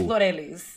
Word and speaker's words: Glorelys: I told the white people at the club, say my name now, because I was shy before, but I'm like, it's Glorelys.